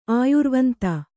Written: ಆಯುರ್ವಂತೆ

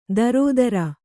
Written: ದರೋದರ

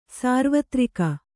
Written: ಸಾರ್ವತ್ರಿಕ

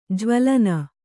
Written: ಜ್ವಲನ

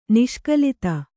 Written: ನಿಷ್ಕಲಿತ

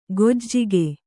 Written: ಗೊಜ್ಜಿಗೆ

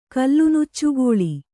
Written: ಕಲ್ಲುನುಚ್ಚುಗೋಳಿ

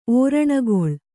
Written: ಓರಣಗೊಳ್

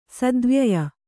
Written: ಸದ್ವ್ಯಯ

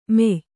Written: ಮೆಯ್

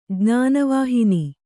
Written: ಜ್ಞಾನವಾಹಿನಿ

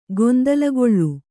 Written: ಗೊಂದಲಗೊಳ್ಳು

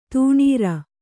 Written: ದಿನಾರು

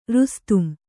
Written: ರುಸ್ತುಂ

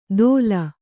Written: ತೋಲ್

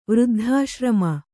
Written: ವೃದ್ಧಾಶ್ರಮ

